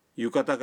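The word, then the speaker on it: یوکاتا (浴衣): یوکاتا کا درست تلفظ ہے یوکاتا.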